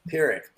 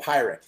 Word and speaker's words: Pyrrhic: Pyrrhic. I said pirate. Pyrrhic.